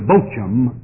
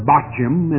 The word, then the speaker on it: Bochim: We have, first of all, the angel of the Lord coming up to Bochim to meet with the children of Israel. Now, it might be that you pronounce the word Bochim, and that's all right. I'm using the English, the Old English pronunciation.